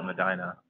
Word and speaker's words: Medina: They also have a It's spelled like Medina, like in Saudi Arabia, but they call it Medina.